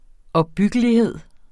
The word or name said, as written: opbyggelighed